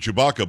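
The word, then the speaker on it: Tshibaka: And her last name is pronounced, you told me to pronounce it Chewbacca, but it's actually T-S-H-I-B-A-K-A. I am saying it right, right? – You are.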